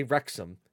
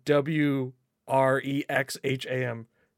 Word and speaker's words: Wrexham: So uh, the village of Ken- like this is this it's in Wrexham, and by the way, I know how to say Wrexham, even though it's spelled W R E X H A M Wrexham.